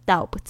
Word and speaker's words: doubt: doubt 注意一下 b 不要发音哦，你不要念成 doubt， OK，会很傻哦。